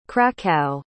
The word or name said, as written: kraków